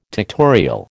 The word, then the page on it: tectorial: American English